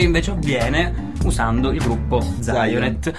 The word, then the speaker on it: Zionet: Oppure abbiamo ancora i nostri contatti Facebook, il gruppo Zionet, scritto Zion con la chiocciola. – Si pronuncia Zionet.